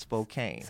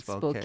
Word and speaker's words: Spokane: It's not Spokane. – So it's not Spokane. It's Spokane. Spokane.